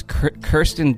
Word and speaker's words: Kirsten: C, Kristen, or how do you say this? Kirsten Dunst.